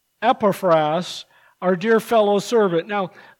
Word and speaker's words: Epaphras: But the right way to say it is Epaphras, according to my Logos click. I clicked on the word and it says Epaphras. So Epaphras. He was a servant.